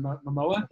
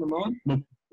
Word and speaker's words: Momoa: Is it Momoa? Right? Is it Momoa? – Yeah.